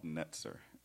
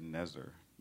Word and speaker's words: Netzer: In 2010, I had the expressed honor and privilege of working with three other friends of mine to create an organization called Netzer, N-E-T-Z-E-R. Some people say Nezer, N-E-Z-N-E-T, but it's spelled N-E-T-Z-E-R, and I'm going to say that T because I want to say that T, N-E-T-Z-E-R.